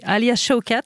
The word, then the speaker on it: Alia Shawkat: Alors, je vais sûrement me vautrer sur la prononciation, car c'est ainsi que je suis. Alia Shawkat, on dit comme ça ? – Exactement.